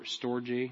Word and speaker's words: storge: storge, I think is how I, I pronounce that. Storge, storge. It uh, doesn't look like storge, but that's how you pronounce it.